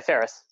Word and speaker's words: Fehres: Yeah, Fehres.